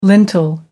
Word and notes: lintel: American English

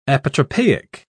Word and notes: apotropaic: British English

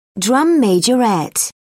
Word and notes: drum majorette: British English

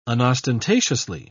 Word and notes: unostentatiously: British English

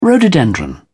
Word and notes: rhododendron: British English

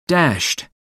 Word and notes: dashed: American English

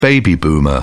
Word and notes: baby boomer: British English

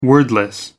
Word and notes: wordless: American English

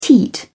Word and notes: teat: British English

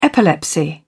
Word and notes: epilepsy: British English

